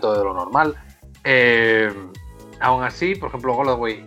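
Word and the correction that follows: Golladay: eh, nunca lo digo bien, pero bueno, el de los Lions. – Goladay. – Goladay. eh, está disponible.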